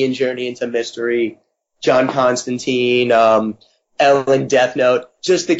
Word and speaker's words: Constantine: The nerd in me wants, like, anytime I say John Constantine, like, the uber nerd in me makes me go, well, it's pronounced Constantine, but after the TV series and everything else, I understand that as far as most people are concerned, it's Constantine, so I can either be that douche, or I can just pronounce it Constantine.